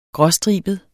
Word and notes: gråstribet: [ ˈgʁʌˌsdʁiˀbəð ]; [ -ˌsdʁiːbəð ]

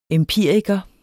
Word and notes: empiriker: [ εmˈpiˀɐ̯igʌ ]; [ em- ]

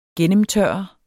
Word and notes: gennemtørre: [ -ˌtɶɐ̯ʌ ]; [ -ˌtɶɐ̯ˀʌ ]

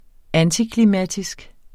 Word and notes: antiklimatisk: [ ˈantikliˌmatisg ]; [ -kliˌmæˀtisg ]

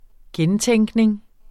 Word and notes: gentænkning: -ˌtεŋgneŋ; -ˌtεŋˀgneŋ

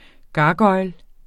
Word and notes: gargoil: ˈgɑːˌgʌjl

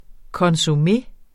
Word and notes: consommé: kʌnsoˈme; kʌsoˈme

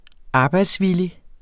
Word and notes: arbejdsvillig: ˈɑːbɑjds-

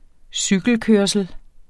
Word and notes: cykelkørsel: -ˌkøɐ̯səl; -ˌkøɐ̯ˀsəl

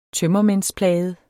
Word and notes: tømmermændsplaget: [ ˈtœmʌmεnsˌplæːjəð ]

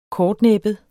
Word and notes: kortnæbbet: -ˌnεˀbəð

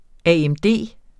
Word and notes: AMD: aεmˈdeˀ; ˈæˀˈεmˈdeˀ

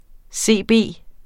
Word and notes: CB: ˈseˀˈbeˀ; seˈbeˀ

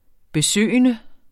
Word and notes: besøgende: beˈsøˀjənə

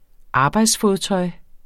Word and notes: arbejdsfodtøj: ˈɑːbɑjds-

